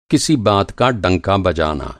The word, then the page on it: डंका: ڈنکا